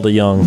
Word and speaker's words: DeJong: I said it wrong. Say it for me. – Paul DeJong.